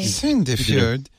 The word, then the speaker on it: Sandefjord: Sandefjord.